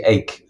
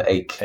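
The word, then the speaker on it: Eijk: Well, I think it's actually Eyck, Willem Eyck. Eyck is how Eyck. That's pronounced. Yeah.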